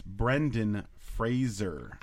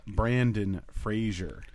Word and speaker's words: Brendan: It's Brendan Fraser. – I don't care. – Not Brandon Fraser.